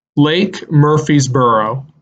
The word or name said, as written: Lake Murphysboro